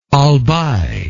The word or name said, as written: albay